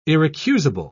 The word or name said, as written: irrecusable